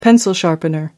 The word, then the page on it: pencil sharpener: British English